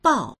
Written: bao4

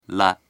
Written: le

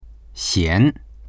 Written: xian2

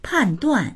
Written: pan4duan4

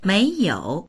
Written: mei2you3

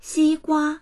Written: xi1gua1

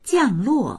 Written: jiang4luo4

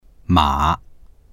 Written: ma3

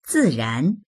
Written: zi4ran2

